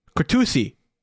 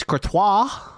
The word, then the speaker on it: Courtois: And I showed her the TikTok and was like, how would you pronounce this last name? And I showed it and she was like, Courtois? – Yeah. – And I was like, better, but it's Courtois.